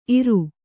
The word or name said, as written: ಇರೈ